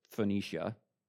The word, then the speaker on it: Phoenicia: That's how you pronounce it, Phoenicia.